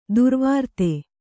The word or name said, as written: ದುರ್ವೃತ್ತೆ